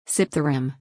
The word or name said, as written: sip the rim